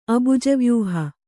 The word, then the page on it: ಅಬುಜವ್ಯೂಹ: Kannada